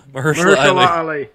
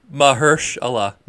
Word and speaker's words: Mahershala: It's got Masahara. That's so mean. Masahara Ali. I think is his name. – Mahershala Ali. – How do you pronounce it? – Mahershala Ali. – Yep.